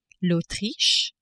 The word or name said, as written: Autriche